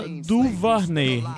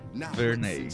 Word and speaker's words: DuVernay: A gente vai começar com a indicação do documentário do Netflix, a 13 terceira emenda, da diretora Ava Duvarney. – Duvarney. Ava Duvarney. – Duvernay.